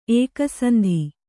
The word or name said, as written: ಏಕಸಂಧಿ